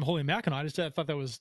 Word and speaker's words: Mackinac: Did you call it Mackinac? – Mackinac. – Is that how you pronounce it? – That's how you pronounce Mackinac.